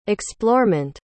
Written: explorement

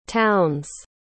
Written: townes

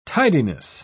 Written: tidiness

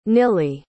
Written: nilly

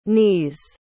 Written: knees

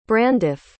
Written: branduff

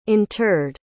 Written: interred

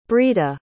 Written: breeda

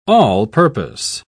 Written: all-purpose

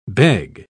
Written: beg

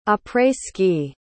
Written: après-ski

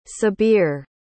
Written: sabir

